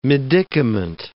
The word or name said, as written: medicament